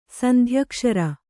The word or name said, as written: ಸಂಧ್ಯಕ್ಷರ